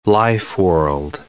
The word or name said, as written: lifeworld